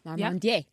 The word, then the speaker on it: Larmandier: The bubbles are not nearly as tight as the Cremant Grand Cru Guy Larmandier. Is that how you say it? – Larmandier. – Yeah. – You were very close.